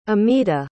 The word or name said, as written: amita